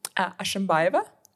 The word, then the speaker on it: Ashimbaeva: But my second name is Ashimbaeva, which is, if you just read it, it's as it's pronounced as it's spelled.